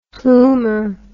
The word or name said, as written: plomer